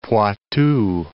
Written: Poitou

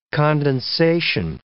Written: condensation

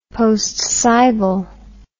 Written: postcibal

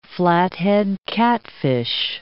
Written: flathead catfish

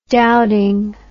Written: Dowding

